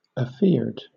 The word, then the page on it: afeared: British English